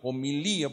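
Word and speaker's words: homilía (ὁμιλία): Y en griego, y homilia o homilía, homilía, porque lleva el acento en la última I, es, significa homilía, es decir, discurso, conversación.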